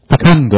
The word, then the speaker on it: fecund: Suppose, because seeds that are intact, unspoiled, not damaged by the wind and the sun's heat, fecund, is that how you pronounce that? Fecund, okay, you're right, fecund.